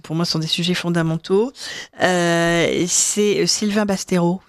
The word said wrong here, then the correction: Bastonero: Vous étiez là? – Sylvain Bastonero. – Ah, Bastonero. Bastonero. – C'est pas grave. – Oui, donc j'ai mangé une syllabe, c'est fou, quoi.